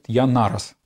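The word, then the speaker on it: Яннарас: Христос Янарас. Может быть, слышали такая фамилия? Его еще иногда произносят Янарас.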